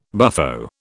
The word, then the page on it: buffo: British English